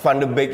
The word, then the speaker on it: Van de Beek: Kalau kalau Van Van Van de Beek ini sadar.